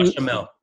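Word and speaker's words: béchamel: Yeah, bechamel.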